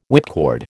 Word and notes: whipcord: American English

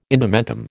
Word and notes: indumentum: American English

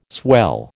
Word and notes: swell: American English

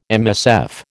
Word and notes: MSF: American English

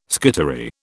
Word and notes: skittery: British English

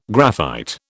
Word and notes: graphite: British English

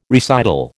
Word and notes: recital: American English

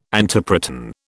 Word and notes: antiproton: British English

